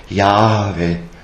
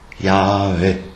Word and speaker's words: Jahwe: Wird geschrieben als Tetragramm, also mit vier Buchstaben, J, H, W, H, interessanterweise, wobei das H wird als leichtes E gesprochen, Jahwe, Jahwe, also das, im H ist der strömende Atem drinnen, das so ein bisschen noch E vokalisiert wird.